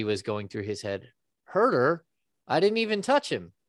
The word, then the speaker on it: Huerter: And then he was forced to foul, and Kevin Huerter went down and knocked down a couple of free throws, which, hey, I don't know how to say his name. – Huerter. – Yeah. – Huerter. Okay. Don't hurt her.